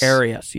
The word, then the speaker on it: Arius: And something I find interesting about this is Arius. Am I pronouncing that right? Arius? – Arius, yes. – Arius, yes.